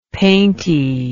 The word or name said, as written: painty